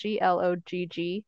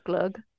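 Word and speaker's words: glögg: So that word is spelled GLOGG. There's an umlaut above the O, so it looks like glog to American eyes, but I think it is pronounced glug.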